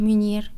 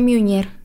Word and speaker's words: Meunier: Meunier. – Meunier. Creo que se pronuncia Miunier.